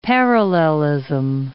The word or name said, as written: parallelism